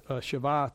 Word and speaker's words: Shabbat: So when you look at the word rest here, it is Sabbath, pronounced Shabbat, and which is in the original Hebrew.